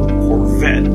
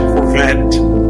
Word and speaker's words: Corvette: Resulta que una de las marcas que siempre, siempre, de todo tiempo me ha gustado es la marca Chevrolet, pero el modelo Corvette. Nosotros, en inglés se dice Corvette, pero en español nosotros decimos Corvette, porque así es como se escribe, ¿no? C-O-R-V-E-T-T-E, Corvette.